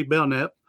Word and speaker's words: Belknap: So, all right, so John here, we just mentioned about the silent K, Belknap.